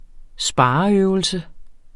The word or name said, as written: spareøvelse